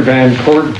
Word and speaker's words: Van Court: My name is Steve Baker, B-A-K-E-R, Commissioner with the Board of Prison Terms. – My name is Arthur Van Court, V-A-N, capital C-O-U-R-T, Commissioner, Board of Prison Terms.